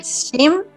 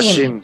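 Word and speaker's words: Schim: S-H, S-C-H-I-M. Sí. Scheme. – Ah, Scheme, sí.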